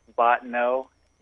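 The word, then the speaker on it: Bottineau: And is that Bofano, North Dakota? – It's Botano. Yeah.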